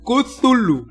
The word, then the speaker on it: Cthulhu: ¿Cómo se pronuncia, cabrón? – Y me investigué. – Cthulhu. – Así se pronuncia.